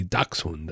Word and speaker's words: dachshund: So, you know, proper German pronunciation would be dachshund, right?